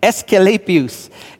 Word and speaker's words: Asclepius: It's Asclepius.